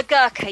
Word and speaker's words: Gurkha: Gokhara Monument. Gokhara Monument. – Which one is that? – It's the soldier. – Sorry. – It's the soldier. – Oh, the Gurkha, yes. – Gurkha, sorry.